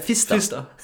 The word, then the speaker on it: Pfister: P-F-I-S-T. – Ja, Pfister.